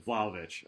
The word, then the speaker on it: Vlahovic: Yeah, Vlahovic.